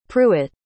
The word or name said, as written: pruet